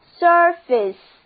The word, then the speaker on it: surface: 我们在 face 之前加上 sur，这个表示上面的前缀，就出现了这个单词 surface，字母组合 ur 读作 a。跟我念这个单词就是 surface。